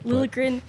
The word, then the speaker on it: Liljegren: Like, as far as, is it Liljegren? Am I saying that right? – That's how I would say it. – Liljegren, as far as him initiating that contact, absolutely.